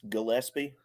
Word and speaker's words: Gillespie: I hope I'm pronouncing that right. John, how's it going? Welcome to the Boneyard Podcast. – It's going pretty well, and it is Gillespie. It's a hard G.